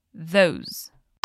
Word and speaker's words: those: Olha lá a pronúncia disso: those. Eu tenho uma, uma diquinha infalível aqui para você. É como se você fosse falar um Z, mas com a língua entre os dentes.